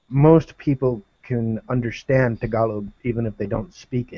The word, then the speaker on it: Tagalog: Tagalog, right? Is the right way to say it, not Tagalog.